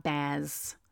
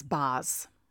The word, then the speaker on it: Baz: It's not Baz with my bad New York accent. It's Baz.